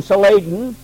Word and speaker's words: Saladin: Not long after that, I read about a famous Asiatic warrior, pronounced his name Saladin.